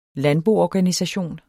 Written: landboorganisation

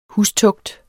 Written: hustugt

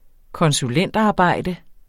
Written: konsulentarbejde

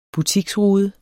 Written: butiksrude

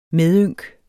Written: MEDYNKS

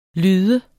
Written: lyd

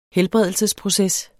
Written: helbredelsesproces

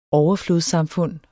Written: overflodssamfund

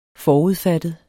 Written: forudfattet

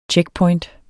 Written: checkpoint

